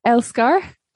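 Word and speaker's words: Älskar: So the album's name is Elksar. Am I pronouncing that right? – Elskar. – Almost. Elskar. Yeah.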